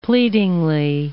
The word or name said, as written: pleadingly